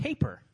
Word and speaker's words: tapir: I think people pronounce it. I think you pronounce it taper.